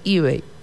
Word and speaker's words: eBay: También un día como hoy, 3 de septiembre, podemos mencionar que se funda eBay, pronunciación en inglés eBay.